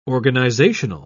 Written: organizational

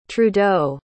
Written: trudeau

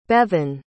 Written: Bevan

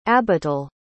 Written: abital

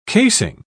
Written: casing

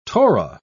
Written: torah

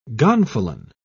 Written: gonfalon

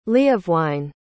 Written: leofwine

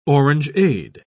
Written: orangeade

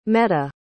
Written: meta

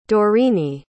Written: Dorine

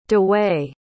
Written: dewei

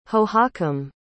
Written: hohokam

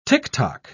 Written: ticktock

